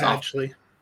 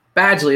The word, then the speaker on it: Badgley: Penn Badgley, I don't even know, Badgley, I guess. I don't know how to say his name. It's Badgley. – Badgley, there we go.